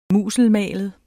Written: musselmalet

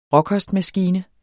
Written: råkostmaskine

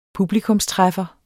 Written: publikumstræffer

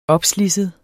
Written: opslidset

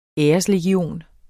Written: æreslegion

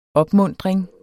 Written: OPMUNTRINGER

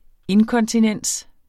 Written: inkonsistens

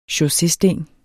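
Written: chaussésten

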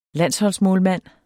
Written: landsholdsmålmand

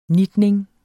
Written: NITNING

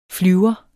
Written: flyver

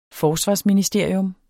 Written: Forsvarsministerium

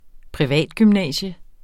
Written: PRIVATGYMNASIUM

